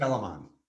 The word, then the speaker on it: Telamon: Telamon.